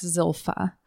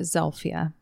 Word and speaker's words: Zilpha: So her name is spelled like Zilpha, but her family pronounced it Zelphia. – Okay. – So that's how I'm going to pronounce it.